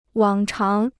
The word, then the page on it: 往常: wǎng cháng